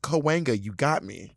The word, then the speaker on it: Cahuenga: It took me a while to get Cahuenga. And LA people are not chill about you mispronouncing the name. They will let you have it.